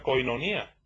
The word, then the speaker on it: koinonía: La comunión, amados hermanos, viene de una palabra griega que se pronuncia koinonía,